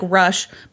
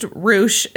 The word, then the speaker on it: Roush: It's spelled like Rush, but it's pronounced Roush.